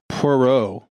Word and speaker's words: Poirot: I would say it's Poirot, like Perot, like Ross Perot, but Poirot. – That's close to how some of the people in the movie said it. – Yeah, because you wouldn't pronounce the T. – Yeah. – It's P-O-I-R-O-T.